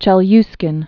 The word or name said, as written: Cape Chelyuskin